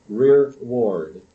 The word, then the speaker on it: rearward: And the armed men went before them, but the reward came after the ark of the Lord. We should probably pronounce it rearward. And it is an English word. It means the rear guard.